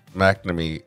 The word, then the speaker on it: McNamee: Jessica McNamee, is that how you say it? McNamee? – It's probably McNamee. – McNamee. Yes, that is correct.